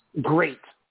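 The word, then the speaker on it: GLEAT: You said it's pronounced Great. Great. Oh, that's stupid. – Yeah, that's why. So it's G L E A T because it's great. I just don't understand the, the L behind it.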